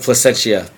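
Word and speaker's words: Plascencia: And placenta, I can't even pronounce. I'm sorry. – FCO placentia. Placenta. – I didn't want to say it. Placentia.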